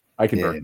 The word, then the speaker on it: Eichenberg: be a guy like Christensen or Eichelberger. I always get his name wrong because there's been so many that are they're approximately, but Liam, who plays for Miami. Eichenberg. Yeah. There you go.